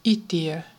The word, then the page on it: itiö: /ˈitiø/